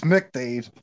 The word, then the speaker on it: MacDade: It's called McDade Boulevard, and but it's spelled MacDade, MAC. But I guess the A would be Yeah. – silent. It's pronounced McDade.